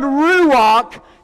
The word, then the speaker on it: ruach: And the tag said, RUACH. Coming up I-65 from Prattville to Clanton. The car beside me comes speeding by about 95 miles an hour. R-U-A-C-H. It's a Hebrew word. It's pronounced ruach.